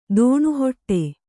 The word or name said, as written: ತನಿಹೊಟ್ಟು